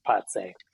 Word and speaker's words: Pace: It is spelled with the American pronunciation of pace, paces, Vilna's Fabrica, but it's pronounced Patse with the lovely Latvian accent.